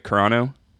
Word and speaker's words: Carano: It's cool to see, like, Gal Gadot and Gina, am I saying this right, Carano? – Mm-hmm.